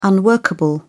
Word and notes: unworkable: British English

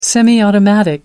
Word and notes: semiautomatic: American English

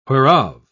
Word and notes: whereof: British English

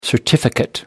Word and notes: certificate: American English

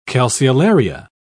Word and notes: calceolaria: British English

